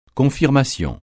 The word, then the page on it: confirmation: francais